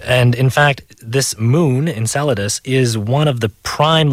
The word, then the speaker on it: Enceladus: It's called Enceladus. – Enceladus.